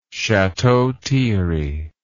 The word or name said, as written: chateau thierry